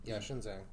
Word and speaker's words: Xinjiang: And it's like, with these Uyghur Muslims, like, in Xinjiang, like, that's how you pronounce it, right? – Yeah, Xinjiang.